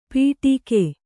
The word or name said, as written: ಪಿಡಿಕೆ